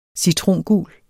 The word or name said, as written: citrongul